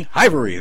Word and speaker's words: Hivery: Well, William Huber, the founder of Hivery, if I'm pronouncing it right. Hivery. There we go. I got it right.